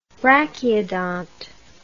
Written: brachyodont

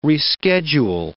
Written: reschedule

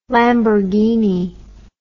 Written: lamborghini

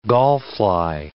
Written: gallfly

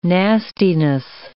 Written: nastiness

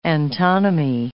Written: antonymy

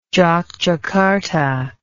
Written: Jokjakarta

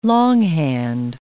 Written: longhand

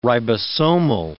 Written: ribosomal

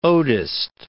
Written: odist

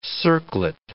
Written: circlet

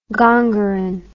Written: gongerine

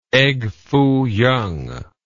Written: egg foo yung

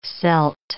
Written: celt

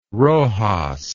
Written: roxas

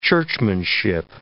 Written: churchmanship

